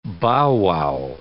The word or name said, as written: bow-wow